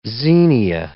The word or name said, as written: xenia